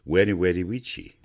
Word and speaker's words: Veni, vidi, vici: Veni, vidi, vici was actually pronounced Veni, vidi, vici. – Sounds pretty lame, eh? Sounds like Elmer Fudd.